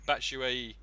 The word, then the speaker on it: Batshuayi: Batshuayi.